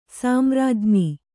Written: ಸಾಮ್ರಾಜ್ಞಿ

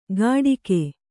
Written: ಘಟಿಗೆ

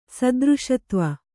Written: ಸದೃಶತ್ವ

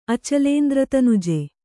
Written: ಅಚಲೇಂದ್ರತನುಜೆ

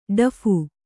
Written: ಡಫು

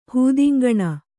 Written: ಹೂದಿಂಗಣ

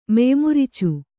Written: ಮೆಯ್ಮುರಿಚು

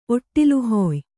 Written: ಒಟ್ಟಿಲುಹೊಯ್

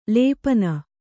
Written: ಲಪನ

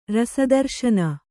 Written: ರಸದರ್ಶನ

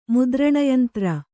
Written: ಮುದ್ರಣಯಂತ್ರ

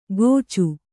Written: ಕೋಚು

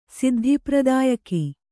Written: ಸಿದ್ಧಿಪ್ರದಾಯಕಿ

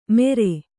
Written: ಮೆರೆ

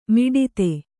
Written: ಮಡದಿ

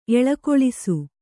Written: ಎಳಕೊಳಿಸು

